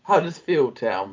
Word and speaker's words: Huddersfield Town: Hutterson Town are in the playoffs. – Oh, I'm glad you butchered it. – Huddersfield Town, but yes.